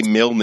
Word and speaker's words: Milne: It's one syllable. – Yes, yeah, Sam. – It's Milne.